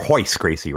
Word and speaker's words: Royce Gracie: Royce Gracie, or Hoyce Gracie, rather.